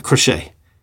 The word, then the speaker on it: crochet: or even I saw one bar had a croquet. How do you pronounce that? Crochet. Knitting. – I don't think it's croquet, like, because that's the thing with mallets and balls.